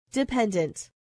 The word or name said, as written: dependent